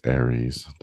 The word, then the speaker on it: Aries: The constellation name Aries is pronounced Aries.